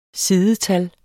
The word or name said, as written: sidetal